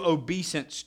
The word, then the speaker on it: obeisance: Proskuneo means to kiss the hand or to bow down before, to show obeisance to. And I'm not mispronouncing that word. It's obeisance, not obedience.